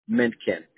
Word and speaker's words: Mintken: J-O-H-N? – Yes. – Okay. And your last name, John? – Mintken. M-I-N. – Oh. – T-K-E-N.